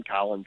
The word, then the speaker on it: Collin: Done a couple documentaries and a few short films, which you can find online if you just look up my name, Colin Suter, C-O-L-L-I-N. Suter is spelled S-O-U-T-E-R, and you can find some of my short films and documentaries online.